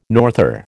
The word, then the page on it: norther: American English